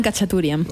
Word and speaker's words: Khachaturian: Se llama Aram Khachaturian.